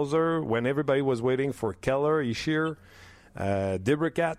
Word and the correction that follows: DeBrincat: Uh, how do you say his name? Debrincat yep.